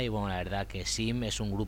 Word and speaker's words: Seam: canción de los Arches of Love os he puesto a Sim, eh, Seam, se escribe Sim, esta canción de su último disco llamado The Pages Global, su canción Get Higher.